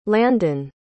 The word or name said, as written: landin